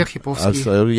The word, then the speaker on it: Архиповский: А вот Гофман 69 сообщает, ребенка мотивирую Архиповским. Правильно я ударение ставлю? Да, Алексей Архиповский.